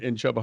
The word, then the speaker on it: Chuba: They are built to give the season the Sooners trouble, because of Chuba, is his name Chuba? Is that how you pronounce that? Chuba Hubbard? – No, it's Chuba Hubbard. – Chubbard Hubbard. – It's just like Chubby Bunny.